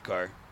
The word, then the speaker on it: Kerr: Carr Mankey, K-E-R-R, like Steve Kerr, but it's pronounced Carr.